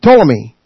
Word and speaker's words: Ptolemy: And the 2 dynasties were descendants of Seleucus, S-E-L-E-U-C-U-S. He ruled an empire from Antioch in Syria, north of Jerusalem, and Ptolemy. P-T-O-L-E-M-Y, where the P is silent.